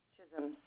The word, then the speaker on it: Chisholm: My first name is Deborah, D-E-B-O-R-A-H. – Okay, last name? – Chisholm, C-H-I-S-H-O-L-M. – Okay, and what is the shipping address? – It's 23 Acadia, A-C-A-D-I-A, Ave.